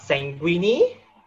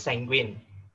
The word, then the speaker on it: sanguine: And the word of the evening is sanguine, not sanguine. I can see how that is confusing for some people because it sounds like the pasta linguinis. But it's not an Italian origin, it's of old French origin, so it's pronounced sanguine.